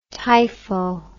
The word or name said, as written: typho